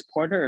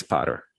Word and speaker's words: Potter: I don't know if it's Porter or Potter. – It's Potter.